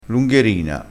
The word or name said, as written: lungherina